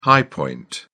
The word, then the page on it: high point: British English